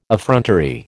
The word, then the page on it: effrontery: American English